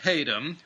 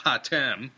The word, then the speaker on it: Hatem: George Haydom, who I openly admit I incorrectly pronounced Hatem, was one of the four in the pantheon of legendary Lao Wai, who came and left their mark in modern China.